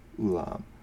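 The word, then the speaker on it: ulam (עולם): The Hebrew word for forever or eternity is pronounced ulam, and it is used 5 times in these verses.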